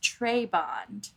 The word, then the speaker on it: Trebond: The pronunciation guide says Trebond, but I've been saying Trebond my whole life. So let's say Trebond. I think tray like a cafeteria tray.